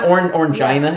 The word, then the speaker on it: Orangina: Half, Orangina yeah. is how it's pronounced.